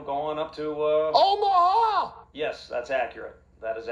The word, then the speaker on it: Omaha: We might uh, we might need you in Omaha. Coach, one more time. It's kind of three syllable. It's rhythmic, right from the diaphragm.